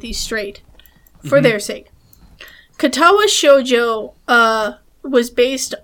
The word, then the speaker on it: Katawa Shoujo: Okay, so yeah, Katawa Shoujo, I think I'm saying it correctly. – Yeah, that's right.